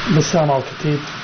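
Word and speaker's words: Bassam Al Khateeb: Bassam Al Khatib, presenting Petra North Inc.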